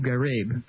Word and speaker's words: Ghraib: I can't pronounce Abu Ghraib correctly. – Ghraib. – I've heard Grabe. I'm going to call it Grabe if I can.